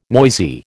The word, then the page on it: noisy: American English